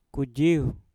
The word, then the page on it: kujiuw: Mpur